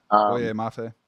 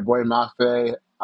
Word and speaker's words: Boye Mafe: Mafe. – Yeah. Boye Mafe. – Yeah, Boye Mafe.